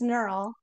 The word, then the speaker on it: Knerl: No, it's Knerl. – Knerl. Okay. I honestly thought so, but I was just like, I don't know, silent K. No, I don't know. – Yep, you're right.